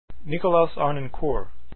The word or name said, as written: harnoncourt